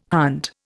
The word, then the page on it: hunt: Indian